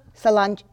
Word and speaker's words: Sláinte: Sláinte. – Sláinte? – Sláinte.